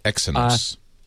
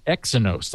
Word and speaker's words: Exynos: those based on and I don't even know how to pronounce this, the Exynos. – Exynos, that's what I would have guessed, or something like that.